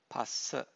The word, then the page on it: phassa: Pali